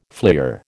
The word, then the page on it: fleer: American English